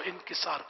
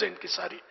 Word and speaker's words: انکسار: انکسار تو مجھ سے انکساری نکل جاتا ہے کہ عام ہے لیکن لفظ انکساری لغتن غلط ہے انکسار صحیح تلفظ